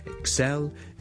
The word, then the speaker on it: excel: 个重音咧，其实咧佢系应该放喺 the second syllable 嘅，so excel， excel， excel。